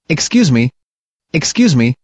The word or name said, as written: excuse me